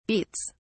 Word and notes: beets(s): British English

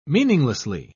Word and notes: meaninglessly: British English

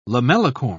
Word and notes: lamellicorn: British English

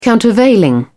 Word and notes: countervailing: British English

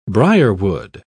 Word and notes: brierwood: British English

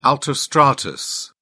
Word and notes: altostratus: British English

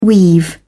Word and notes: weave: British English

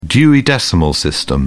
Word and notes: dewey decimal system: British English

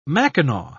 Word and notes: mackinaw: British English

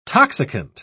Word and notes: toxicant: British English

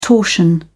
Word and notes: torsion: British English

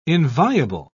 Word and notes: inviable: British English

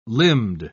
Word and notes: limbed: British English

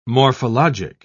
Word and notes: morphologic: British English

